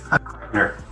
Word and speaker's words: Kreitner: Richard, am I pronouncing your last name correctly? – Kreitner.